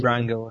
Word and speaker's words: Rango: Oh, actually, Ringo looks good too. Or Rango? Is that how you say it? Rango, I think.